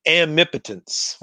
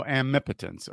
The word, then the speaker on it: amipotence: So how do you pronounce it? I say it amipotence. – Oh, amipotence. Okay.